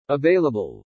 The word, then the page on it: AVAILABLE: English